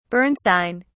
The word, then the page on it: bernstein: British English